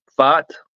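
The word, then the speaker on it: Pfaadt: And I never could figure out how to say this dude's name. Fott? Is that what it is? – I think so, yeah.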